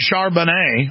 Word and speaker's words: Charbonnier: We're also going to read some of the statements of Charlie Hebdo's editor, Stéphane Charbonnier. Have I pronounced that right? Wow, I pronounced it right.